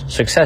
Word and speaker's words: success: For success, success 重音在后啊，lies in。